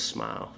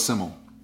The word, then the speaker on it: Smyl: S-Y-M-L. This is called Smile. – Simmel. Simmel is the Welsh word for simple. – Well, makes sense. – I had to reach out to the record label to find out how to pronounce S-M-Y-L. – Because I thought it was Smile, you know? – Yep.